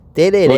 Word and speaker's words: tereré: Vai vir uma pessoa aí pra trazer a pronúncia certa de como falar tereré, ok? É tereré, okay.